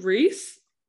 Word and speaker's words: Rhys: Is it Rhys when it's R-H-Y-S? – That's how I've pronounced it.